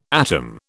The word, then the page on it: atom: British English